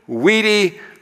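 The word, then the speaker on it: Vidi: Mrs. Pichotti taught us to pronounce the V's as W, and so she taught us to pronounce this Wenny, Weedy, Wicky, which translated, Julius Caesar says, I came, I saw, I conquered.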